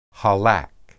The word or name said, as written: halak